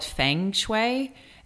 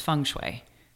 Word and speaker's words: Feng Shui: And she also was like, I used to call it Feng Shui, and she's like, no, it's Feng Shui.